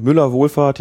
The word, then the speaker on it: Müller-Wohlfahrt: Ja, und wer Hans, Miller, Hans Wilhelm Müller-Wohlfahrt, jetzt habe ich ihn richtig ausgesprochen, draußen stehen hat, der wird sich darauf verlassen können, dass der schon was das betrifft, die richtige Entscheidung trifft.